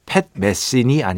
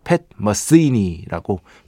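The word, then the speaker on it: Pat Metheny: 네, 이거 배철수의 음악 캠프에 직접 출연해서 팻 메시니 아니고 본인께서 팻 머스이니라고 발음을 교정해 주셨던 추억이 지금도 떠오릅니다.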